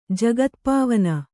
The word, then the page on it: ಜಗತ್ಪಾವನ: jagatpāvana